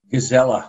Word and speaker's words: Gazella: Gazella. – Gazella. – Last name, actually the Arabic version of gazelle.